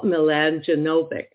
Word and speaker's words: Mladjenovic: And I'd like to welcome to our show today, Paul Mladjenovic.